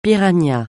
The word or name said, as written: piranha